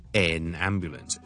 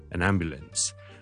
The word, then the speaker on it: an ambulance: 譬如一个救护车，an ambulance，即系佢就好将佢读成咧，an ambulance，咁咧就系怪怪地嘅。正常呢个字嘅发音咧，其实咧系就咁好弱、好轻嘅 followed by an N sound，so an 一个救护车，an ambulance。